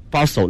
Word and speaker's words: bustle: 是 hustle 和 bustle 那个 t 是不发音的。